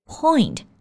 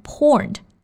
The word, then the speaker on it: point: Point 这个单词呢，发音要注意，不要读成 point，中间的元音啊是 oi 双元音 oi。所以是 point, beside the point.